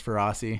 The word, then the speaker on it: Feraci: I guess you can pronounce my name as Feraci if you want to.